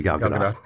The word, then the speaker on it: Gal Gadot: Is that actually how you pronounce it? – It is actually Gal Gadot.